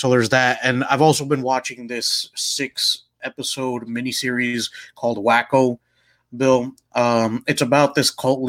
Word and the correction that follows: Waco: Do you mean Waco? – Is that how you pronounce it? – Yes, this is Waco, Texas.